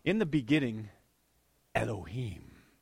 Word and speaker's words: Elohim: The word looks like this in English. E-L-O-H-I-M. It's pronounced this, Elohim. Let's just say it, will you?